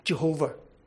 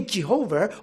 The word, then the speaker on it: Jehovah: Two-syllable is pronounced, therefore, as Yahweh. Or three-syllable becomes Jehovah. So whether you say Jehovah or Yahweh, the attempt there is to try to translate the personal name of God.